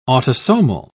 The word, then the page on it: autosomal: British English